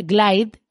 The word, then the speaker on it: Glide: Glide, se escribe G-L-I-D-E.